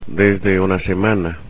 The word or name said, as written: desde una semana